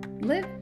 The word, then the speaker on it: live: I'll spell it for you, L-I-V-E. What does it say? Live or live. Both are correct. It changes based on the context. I live in the United States. I take live English classes every Wednesday. Same word, same spelling, different pronunciation based on the way it's being used.